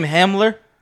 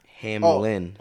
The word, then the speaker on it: Hamlin: So it was like, I think he got real lucky that Hamlin was. – Oh, nah, yeah. Saved. The tweet was crazy, but now it's even extra crazy. – The timing was terrible, bro. – Isn't his name Hamler? – Hamlin.